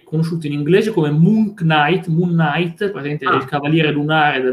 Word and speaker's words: Knight: Darkness della Top Course, Darkness è quello, ci sono due videogiochi, Moon Knight, Moon Knight, l'ho scritto così, si pronuncia Knight, però la K è silenziosa, come, come la, la D di Django.